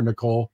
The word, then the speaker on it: Nicol: Because I always look at that time when he had the illness, the tonsillitis, whatever it was, and Jimmy Nicol, they pronounce it Nicol or Nicole? – Yeah, like, Nicol, yeah.